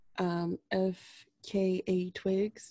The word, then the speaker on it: FKA Twigs: I know a little bit about FKA Twigs.